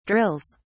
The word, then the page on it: drills: British English